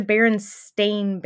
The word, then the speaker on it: Berenstain: It's not the Berenstain Bears. It's the Berenstain Bears. – Listen. – It is spelled STAIN at the end. – Listen. – Not steam.